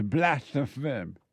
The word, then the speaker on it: blasphemous: I want to know, is this blasphemous? Did I say it right? – Yeah.